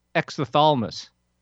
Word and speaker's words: exophthalmos: The P is throwing me off. If it's a silent P, then it's just exophthalmos.